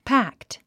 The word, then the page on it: packed: pǽkt; パァックト